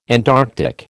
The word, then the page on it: Antarctic: American English